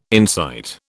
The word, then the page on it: insight: British English